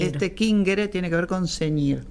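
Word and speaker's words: cingere: Kingere en el latín clásico y chingere, pero se escribe singere con singere.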